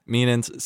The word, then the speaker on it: Mealins: Mealins. So a lot of people say Mealings.